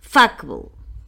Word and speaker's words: fuckable: Fijaos dónde pongo el acento, en la primera sílaba, como en vegetable, no es vegetable, vegetable, comfortable, shackable, fuckable acento en la primera sílaba.